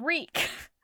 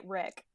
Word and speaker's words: Reek: So, oh, like, I was pronouncing it Rick. – Nope, it's Reek. – This is just Rick. – It's the Reek.